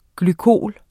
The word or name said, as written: glykol